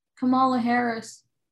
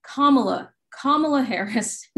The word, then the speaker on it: Kamala Harris: Do you think that Kamala Harris, I need to stop saying that. Kamala Harris is her name, which I think speaks to the fact that I can't pronounce her name speaks to something too.